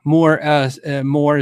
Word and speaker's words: MOOER: More is the company, MOOER, pronounced more, like moo r.